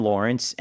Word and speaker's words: Laurence: Lawrence, and Lawrence is spelled L-A-U-R-E-N-C-E. So like Lauren, but Lawrence.